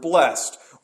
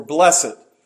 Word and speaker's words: blessed: And we begin this Beatitude as we begin each of them, and that is with the word blessed or blessed. My wife called me out for switching pronunciations this week. Sometimes I go back and forth.